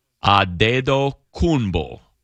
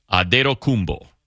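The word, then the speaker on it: Antetokounmpo: But they have his last name as Adetokunbo. Adetokunbo. Not me putting nine extra syllables in it. Adetokunbo.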